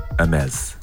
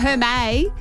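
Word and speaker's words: Hermès: Hermes. – Ding, ding. – Oh, and he even said it right. – He did. Not Hermes.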